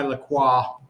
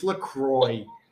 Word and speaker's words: La Croix: Thank you for pronouncing it correctly, darling. – It's from Wisconsin. It's La Croix. – It's La Croix, darling. – Pamplemousse La Croix from Wisconsin. – La Croix, like your dress.